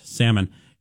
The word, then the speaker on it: salmon: Also, most types of fish, including salmon, notice I didn't pronounce the L in that, salmon,